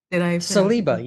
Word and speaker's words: Saliba: Saliba, you have it perfect.